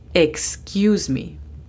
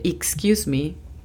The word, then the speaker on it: excuse: Toma nota de la pronunciación de la E. Sé que es un poquito confuso porque te acabo de decir que la letra E se pronuncia I, pero como es el inglés un idioma no fonético, hay muchas excepciones y esta es una de ellas. La palabra no se dice excuse me, se dice excuse me, como si fuera una E en español.